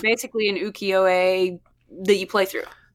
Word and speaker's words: ukiyo-e: it's a Japanese art style called ukiyo-e.